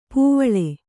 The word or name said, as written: ಪೂವಿಲ್